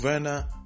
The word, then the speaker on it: Werner: And in German naming custom, anything that is W is pronounced as V. So it's Timo Werner, actually.